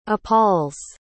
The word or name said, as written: appalls